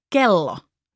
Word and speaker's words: kello: Then we have K, like in kello, meaning clock or watch.